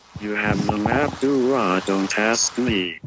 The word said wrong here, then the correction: Dora: Damn it, I can't say Dora. – Dura. – Wait. – That's actually her Islamic counterpart. – I think he can say it right this time. – You have the map, Dora. Don't ask me. – Close enough.